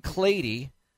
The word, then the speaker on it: Clady: The next tackle to come off the board, pick number 12, Ryan Clady. Rhymes with lady.